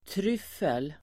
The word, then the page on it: tryffel: [²tr'yf:el]